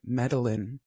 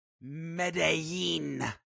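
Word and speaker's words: Medellín: Because of the way that you pronounced the city and you said Medellin. – Oh. – It's Medellin.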